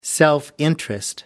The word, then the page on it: self-interest: American English